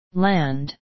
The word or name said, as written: land